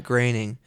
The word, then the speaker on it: Groening: Matt Groening.